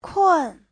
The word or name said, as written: kun4